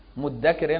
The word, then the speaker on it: مدكر: مدكر هنا بمعنى مذكر، مذكر بس يعني تسهيلا في النطق الذال دخلت في الدال وبقت مشددة،